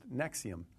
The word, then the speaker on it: NXIVM: And when we first reported on Raniere's arrest last month, we only knew to refer to the organization by its initials, NXIVM. But we now know it's pronounced NXIVM.